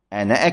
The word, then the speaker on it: أنا: فالحاصل أن الألف في أنا تثبت أثناء، عفوا، تثبت عند الوقف عليها وتحذف عند الدرج في أثناء الكلام.